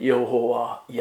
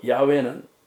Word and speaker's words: 야훼: 여호와, 야훼. 이제 프로난시에서 할 수 없기 때문에 뭐 야훼, 여호와라고 해요. 그것이 여호와는 이제 그리스식이고 야훼는 히브리식으로 이제 발음하는 거죠. 그게 야훼.